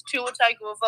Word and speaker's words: Tagovailoa: Like, people now know it's Tua Tagovailoa.